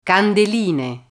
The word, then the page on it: candeline: Italian